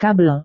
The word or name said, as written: kabel